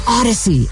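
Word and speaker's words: Audacy: Odyssey, A-U-D-A-C-Y. I know it's spelled weird. And make sure to tune in tonight at 9 o'clock on the Odyssey app for a meeting of the Tortured Poets Department.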